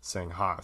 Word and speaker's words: Singha: and every single person was pronouncing it Singha. So I guess if you want to find it or if you want to order it, pronounce it Singha, but otherwise it's Sing soda water.